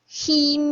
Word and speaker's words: 희: 꺼 져 버 리 던 희 미 한 불 빛 大家注意这儿啊这个读成 he 啊 he 这个音。